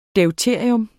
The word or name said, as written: DEUTERIUM